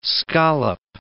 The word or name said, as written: scollop